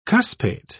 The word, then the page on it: cuspate: British English